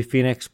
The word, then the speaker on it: Foenix: I understand it's supposed to be Phoenix, but it's spelled F-O-E-N-I-X, which is not how you spell Phoenix.